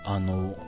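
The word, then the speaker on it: 穴太: 穴に太いと書いて、あの、って読むんですけど、ただ、近鉄の、あのと字は同じなんですけど、近鉄はあのうなんですけどね。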